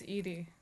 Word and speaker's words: Edie: I think it's Edie. – Edie Sedgwick. I've been saying that wrong.